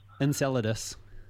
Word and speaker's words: Enceladus: Enceladus. – Yeah. – Okay. – Yeah. So this is basically a report came out from the Webb Space Telescope about Enceladus.